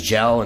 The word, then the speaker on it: Gal: Through the whole movie, it's pronounced a bunch of different Cockney ways, but the name they're calling him is Gal. It sounds like Gal about half the time, and sometimes Gel and Jim Schnell and every other fucking thing.